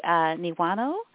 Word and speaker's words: Niwano: And we're so excited this morning to have Masashi Niwano.